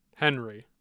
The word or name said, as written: Henry